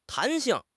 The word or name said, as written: 弹性